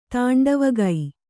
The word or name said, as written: ತಾಂಡವಗೈ